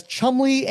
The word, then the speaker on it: Cholmondeley: They're sounded as Chumley and Fanshawe.